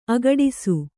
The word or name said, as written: ಅಗಡಿಸು